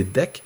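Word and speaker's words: deque: Ou, ben, ça s'écrit de là, mais c'est DEC.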